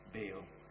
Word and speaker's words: Baal: B-A-A-L, Baal. Or as the Hebrew pronounce it, Baal.